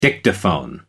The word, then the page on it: dictaphone: American English